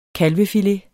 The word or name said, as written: kalvefilet